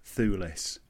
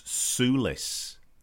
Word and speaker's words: Thewlis: I noticed a commentator pronounced Josh Thewlis, Joss Thewlis. – I thought he was more Josh Soulis. I thought he had him there. I kept playing it back. There was no TH in there, though, whatever happened.